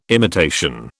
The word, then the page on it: imitation: British English